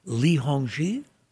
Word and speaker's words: Li Hongzhi: Li Hongzhi? – Yes.